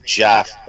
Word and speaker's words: Geoff: one of them is guy's named Jeff, but it's spelled G-E-O-F-F.